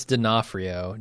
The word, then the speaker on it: D'Onofrio: No, it's D'Onofrio. D-O. Yeah, no, it is. O-N-O-F-R-I-O.